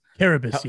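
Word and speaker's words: Carrabis: But I can't pronounce, I'm not sure if I'm pronouncing his name correctly. Jared Karabas. – Karabas, yes. – Karabas, okay.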